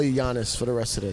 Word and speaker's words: Yannis: Yannis. – There's like an I in there. Yannis. Yannis Pappas. Pappas, the P almost sounds like a B.